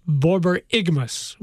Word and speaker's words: borborygmus: Mine is pronounced borborygmus with the emphasis on the ig.